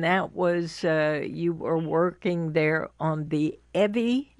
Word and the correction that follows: EV1: am I pronouncing that? – The EV1, yes.